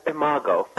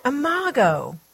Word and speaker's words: Imago: Am I saying that right? – Imago. – Imago. Okay.